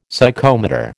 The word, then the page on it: Psychomotor: American English